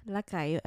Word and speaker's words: Lacayo: On Instagram as SCH Lacayo.